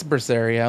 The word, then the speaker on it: Berseria: And I took the, I picked up Tales of Berseria, Berseria, whatever. Uh, and let me tell you, something, you know, no, something like that. I don't know. It's Berseria. I'm looking at it right now.